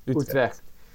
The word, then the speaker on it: Utrecht: But going into Tilburg and Breda and Utrecht, if I said it right. – Yeah, how do you say it? Utrecht. was just absolutely amazing.